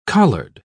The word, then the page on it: collard: British English